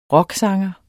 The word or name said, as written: rocksanger